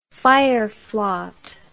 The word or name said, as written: fireflaught